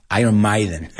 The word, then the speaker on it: Maiden: Made in England by Iron Maiden. And also, ya que sabéis la palabra, y no se pronuncia Maiden. Iron Maiden. It's May. Like como el mes, mayo.